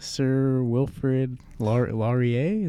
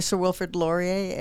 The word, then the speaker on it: Sir Wilfrid Laurier: You guys just got off the Sir Wilfrid Laurier. Is that, did I say that correctly? – Yeah, that's right. It's a Canadian Coast Guard cutter. – Okay. – The Sir Wilfrid Laurier.